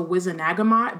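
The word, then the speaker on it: Wizengamot: In the movies, they call it the Wizenagamot, but then that doesn't make sense because he adds an extra syllable.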